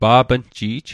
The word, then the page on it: pa'pnji'j: pa'pn'ji'j; Mi'gmaq